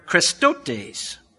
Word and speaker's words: chrestotes: Our English word kindness comes from a Greek word krestotes. And if you're taking down notes, I'll spell it for you. It's spelled C-H-R-E-S-T-O-T-E-S. Krestotes.